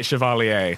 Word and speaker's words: Chevalier: How do you say that? Chevalier, like a knight? – I say it Chevalier.